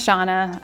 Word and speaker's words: Shawna: My name is Shawna. I work at Awana. It's awkward. It rhymes.